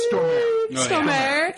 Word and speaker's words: Stormare: I love Peter Stormare. – Stormare.